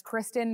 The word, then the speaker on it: Kristen: And I don't know about you, but growing up for me, I had a lot of people mispronounce my name. I don't think it's overly hard. It's Kristen, in case you didn't know. And I would get Kirsten, Kirsten, I would get Christian.